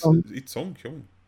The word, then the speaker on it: Song-kyung: His daughter, Su-won, and the wife, Song-kyung. I'm sorry, I'm butchering this name. – It's hard. Song-kyung? Yeah, it's Song-kyung.